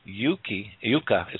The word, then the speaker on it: Yucca: And it's her name is spelled out Y-U-C-C-A Rose, and it's, you know, Yuka is what they call it. Yuka Rose, you know, is a correct pronunciation of it when Jethro can spell.